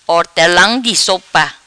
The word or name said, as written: Hortelam Di Sopa